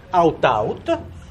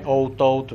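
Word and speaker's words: aut-aut: out-out. Che è il latino, e quindi si scrive così, non è out-out, perché ogni tanto magari qualcuno mi fa out-out, fuori-fuori, dico, oh, perfetto, andiamo via così tutti tranquilli.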